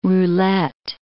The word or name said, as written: roulette